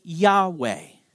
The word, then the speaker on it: Yahweh: We pronounce it Yahweh.